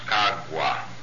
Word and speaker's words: Khauga: It is spelled K-H-A-U-G-A, and it is pronounced Kagwa.